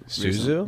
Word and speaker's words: Su Zhu: Uh, uh, I'm definitely going to mispronounce his name, but Zoo was on a podcast. – Su Zhu? – Zuzu, yeah. Su Zhu. Suzu.